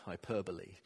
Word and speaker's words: hyperbole: I couldn't pronounce it. When I read the word, hyperbole is what I came up with. I don't know why English is so difficult to read. But Hermie, who knows more about these things, told me it's pronounced hyperbole.